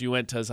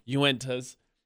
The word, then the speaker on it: Uintas: It's Uintas, I know, I've learned. Uintas.